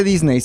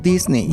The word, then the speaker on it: Disney: No se dice Disney, se dice Disney. Disney.